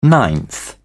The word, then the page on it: ninth: British English